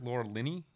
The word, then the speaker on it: Laura Linney: Laura Linney? – Yep.